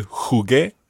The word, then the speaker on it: jugué: And what would happen to the jugué? – You would need a UE. – Exactly. You would spell that J-U-G-U-E acute. Jugué. – Jugué. – But the U is silent in there.